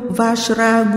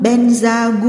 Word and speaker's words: Vajra: Câu chú tôi khuyên các học trò tôi đọc là Om Ahum Vajra Guru Padma Siddhihum. Người Tây Tạng nói Om Ahum Benza Guru Pema Siddhihum là thần chú của Padmasambhava, của tất cả chư Phật, các bậc thầy và những bậc đã chứng ngộ.